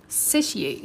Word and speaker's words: satiate: I know that's not sedate. Come on, Google, talk. – Satiate.